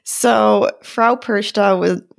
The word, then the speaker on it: Perchta: So it's Frau, and I looked up, it looks like people pronounce it Perkta, Perchta. I'm sure there are other ways that people pronounce it, but I believe from my years in German class that it's pronounced Perchta, like SH, not a hard CH.